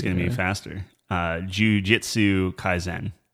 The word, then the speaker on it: Kaisen: Kaisen rhymes with 10.